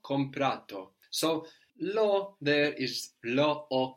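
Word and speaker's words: l'ho: So it sounds just like it lo by itself, no? Lo. But it's actually lo o.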